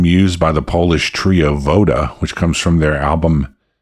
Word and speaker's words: Voda: Up next, I've got something from a Polish band called Voda, spelled like Yoda but with a V.